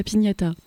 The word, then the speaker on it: piñata: Mais je serais curieuse d'écouter cette chanson « Éclate la piñata ». – Ouais, bah c'est... Ouais. Alors, je l'ai mal prononcé, ou non, c'est la piñata.